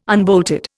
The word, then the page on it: unbolted: Indian